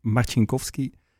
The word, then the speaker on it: Marcinkowski: I do have an extremely difficult last name. It's pronounced Marcinkowski. I got it because of my Polish roots.